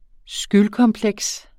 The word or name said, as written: skyldkompleks